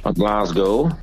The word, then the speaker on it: Glasgow: Glasgow, bo tak się wymawia nazwę tego pięknego miasta w Szkocji.